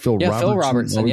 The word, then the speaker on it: Phil Robertson: Obviously, we know that the Duck Commander himself, Phil Robertson, did I say that right? – Phil Robertson.